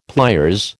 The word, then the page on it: pliers: American English